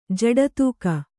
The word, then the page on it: ಜಡತೂಕ: jaḍa tūka